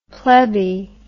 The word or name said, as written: plebby